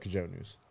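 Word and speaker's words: Cojonú: Speaking of your pronunciation, which we haven't spoke of in about 15 minutes, I'm going to say that because of the fact that it's a Spanish word, and if you look at the tatuaje, the J is a H, it's probably an H here, too. It's probably the Cojonu.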